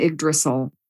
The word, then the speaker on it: Yggdrasil: But in the Norse myths, it's called Yggdrasil, and it's spelled Y-G-G-D-R-A-S-I-L, Yggdrasil.